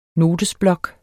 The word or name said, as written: notesblok